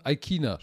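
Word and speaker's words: Ikhine: Sie haben Tannehill, sie haben Derek Handy, sie haben Robert Woods, AJ Brown, Westbrook Aikini oder Aikina. Aikina spricht man den aus.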